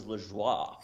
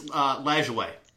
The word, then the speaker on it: Lajoie: I always thought it was Lajoie. – It's Lajoie.